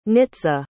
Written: nitza

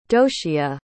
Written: doshia

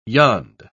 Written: yond

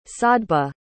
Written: sadhbba

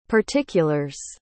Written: particulars

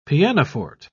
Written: pianoforte

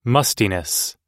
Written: mustiness

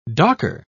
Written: docker

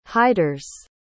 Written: hiders

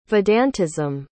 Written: vedantism

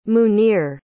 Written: munir

